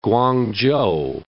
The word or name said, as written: Kuang-chou